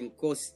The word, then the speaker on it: Nkosi: Nkosi.